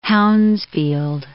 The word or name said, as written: Hounsfield